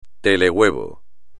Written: telehuevo

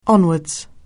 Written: onwards

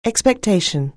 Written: expectation

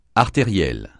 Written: artériel

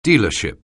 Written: dealership

